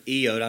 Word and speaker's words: iota: For truly I say to you, until heaven and earth pass away, not an iota," that's how you pronounce that word, iota. I know we say not one iota, that's fine, but the word is iota, not an iota, not a dot, "will pass from the law until all is accomplished.